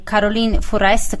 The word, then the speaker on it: Caroline Fourest: Ecco, questa è tratta da diretta, è l'opera prima di Caroline Fourest, correggimi se lo pronuncio male, ovviamente, perché io qua francese. – No, pronunciato bene.